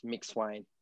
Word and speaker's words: Mcsweyn: Um, someone asked me to ask you, how do you pronounce your last name? – Um, so yeah, over the years I've he- heard a whole different um, range of things, but the correct pronunciation is Mick Swain.